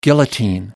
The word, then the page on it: guillotine: American English